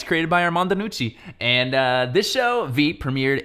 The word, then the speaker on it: Iannucci: So, hey, Veep was created by Armando Iannucci, which is actually how I thought you say his name, but we were watching the commentary, and he said his name like this, Armando Nucci.